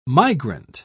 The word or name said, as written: migrant